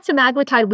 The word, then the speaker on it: semaglutide: So actually, guys, did I pronounce that correctly? You're closer to GLP-1s than I am. – That's how I say it. – Cool. All right. Thank you. You say got semaglutide weekly for 52 weeks.